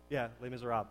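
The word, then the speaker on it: Les Misérables: It's, if any of you have seen, I don't know how to say it, Les Miserables, Les Miserables, that's it.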